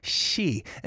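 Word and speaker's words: sa (四): Number 4 is pronounced sa in Korea, and in Japanese culture is pronounced shi, and both means death in the Hanja or Chinese letter.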